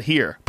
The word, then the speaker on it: hir: Z, H-I-R, hers. – H-I-R-S, Z. Z-H-E-E. Here, yeah. Here's, here, here. How about here? Pronunciation. H-I-R is here. It's not even her.